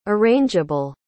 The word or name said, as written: arrangeable